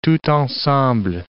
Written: tout ensemble

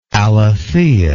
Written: alethea

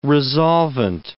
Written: resolvent

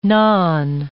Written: nan

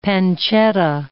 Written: pancetta

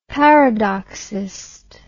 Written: paradoxist

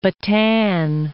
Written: Bataan

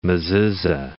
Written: mezuza

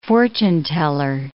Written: fortune-teller

